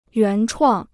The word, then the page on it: 原创: yuán chuàng